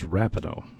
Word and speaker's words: Rapinoe: Am I saying her name right? Rapinoe or is it Rapinoe? Do you know? Is it Rapinoe or Rapinoe? I never know. The soccer player. – It's Rapinoe.